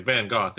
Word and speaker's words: Van Gogh: Like, Van Gogh is how you pronounce his name.